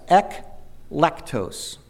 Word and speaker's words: eklektos: E-K-L-E-K-T-O-S, eklektos, eklektos.